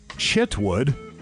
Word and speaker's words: Chitwood: Here is Sheriff Mike Chitwood, C-H-I-T-W-O-O-D. I do hope I announce pronunciation. – It sounds right to me, yeah.